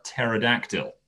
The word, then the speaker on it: pterodactyl: This is called P is for Pterodactyl. Of course, the pterodactyl being the enormous flying dinosaur with the cat with the silent P at the beginning.